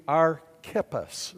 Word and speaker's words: Archippus: Okay, but his name's Archippus, and that's how we pronounce it in English. But if you really want to pronounce it the way we should in English, it would be Archippus. Look at somebody say Archippus. So you see, we look at the CH there, and we think Chip, it's Kip.